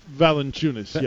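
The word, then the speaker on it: Valančiūnas: Do you know how to pronounce it? – Valanciunas, yeah. – Valanciunas. Yeah, I think I said that.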